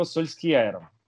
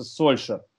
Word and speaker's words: Сульшер: Он говорит, не так произносится, произносится Сольшер.